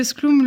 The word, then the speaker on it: Sklum: Et donc, Sclum euh, Skloom,